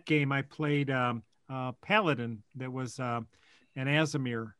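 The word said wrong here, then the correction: Aasimar: Azimar. – Is that how you say it? – Yeah.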